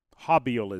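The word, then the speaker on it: Haubeil: He says his name. Hobiel. Hobiel is how it ends up being pronounced from Ohio State.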